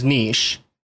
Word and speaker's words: niche: The most substantial part of this podcast probably will be, how do you pronounce N-I-C-H-E? – I think the official way from the root word is niche, and America, doing what we do in America, we make things our own. We drive on the side of the road that we want to. We have Fahrenheit. We have miles. We don't do metric, and we say niche.